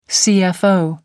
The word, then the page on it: cfo: American English